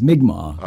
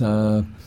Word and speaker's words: Mi'kmaq: The middle piece is commonly known as the Mi'kmaq song, or the correct pronunciation is Mi'kmaq. – Okay. – Right? And it's, so that's Mi'kmaq.